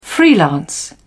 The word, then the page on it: freelance: British English